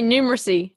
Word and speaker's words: numeracy: You just say numeracy. – Yeah, I'm adding that. – Numeracy. – I'm adding that unnecessary syllable.